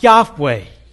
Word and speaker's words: Yahweh: In the Hebrew, the first word, the second one is what we've taken it to be pronounced, Yahweh, his covenant name by which he revealed himself.